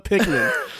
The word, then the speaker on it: Pikmin: But I know you sure ain't saying it right. – What is it? – You said Pikmin. – I said Pikmin. – You said Jesse Pinkman. You said everything but Pikmin. – Oh, Pikmin. Yeah.